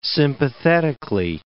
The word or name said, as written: sympathetically